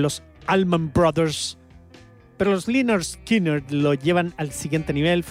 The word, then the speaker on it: Lynyrd Skynyrd: Mira, se pronuncia Lynyrd Skynyrd. Así, tal cual. Está escrito y uno tiene que leerlo. Dice pronounced Lynyrd Skynyrd, con una adaptación que tampoco es como escritura fonética, pero intenta emular una escritura fonética